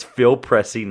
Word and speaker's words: Pressey: Phil Presley, sorry, I always get his name wrong, Phil Pressey, it's Phil Pressey, not Presley, Rajon Rondo, Jared Sullinger, another one I'm really surprised that no one got, Jared Sullinger, Sully.